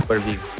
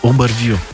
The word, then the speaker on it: UberVu: În trecut, uh, a fondat și a vândut spre Hotsuit proiectul UberVu. Cum se pronunță? Eu am zis bine? UberView. UberView, ok Uh, și uh, a și lucrat apoi uh, pentru Hotsuit, care a achiziționat uh, UberView, așa se spune.